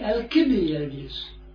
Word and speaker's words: Alcibiades: So if I use the Greek kappa, because it's spelled with a kappa, then it would be Alcibiades.